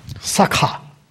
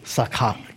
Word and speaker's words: sakha: So, Sakha, K-H long A, Sakha, K-H-A long, Sakha.